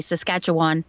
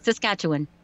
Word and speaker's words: Saskatchewan: I actually did a job for someone in Saskatchewan, and I was trained to say Saskatchewan, and they corrected me. They said, why do you keep saying that? And I said, oh, well, I'm Canadian. That's how you say it. They go, no, it's not.